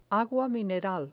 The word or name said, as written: agua mineral